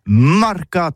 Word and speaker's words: marcats: Eh bien, dis ça à tous, le mercat de l'Uni ou le mercat des rebelles, mais en gascogne toulousain, c'est prononcé sur les marcats, avec un A Marque. En gascogne toulousaine, bah, du côté par exemple de l'île des Baches, c'est l'île Jourdain, pour parler du marché, vous prononcez les marcats.